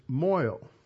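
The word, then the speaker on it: mohel: the way that gets pronounced is moil. And so when you have a son, then 8 days, you go to the moil. And the moil performs the circumcision.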